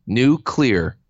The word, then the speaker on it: nuclear: No, I've been practicing. New clear. – Yeah. For those of you who missed it, usually says it wrong.